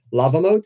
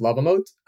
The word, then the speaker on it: LavaMoat: Lava Mode. So you spell it Lava, L-A-V-A, and then Mode would be M-O-A-T. So just combine them and you get Lava Mode.